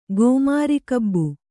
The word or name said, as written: ಗೋಮಾರಿಕಬ್ಬು